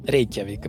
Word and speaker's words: Рейкьявик: Так все-таки какое ударение, ты знаешь? – Я думаю, Рейкьявик. – Правильно. Рейкьявик. Мы говорим, я би и Рейкьявик. если по-исландски. Я би и Рейкьявик.